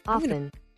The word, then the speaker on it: often: Often. – Did you hear that? This is how you pronounce it correctly. – Often. – Often. – Yeah. – So it says A-A-F-N is how you pronounce it.